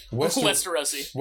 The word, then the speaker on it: Westerosi: Westerosians. – How do you say it? – Westerosi. – Westerosi? Westerosi. Like, multiple cacti.